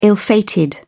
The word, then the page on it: ill-fated: British English